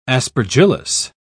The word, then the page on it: aspergillus: British English